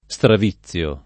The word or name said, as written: straviziare